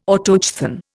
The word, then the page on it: Autochthon: Indian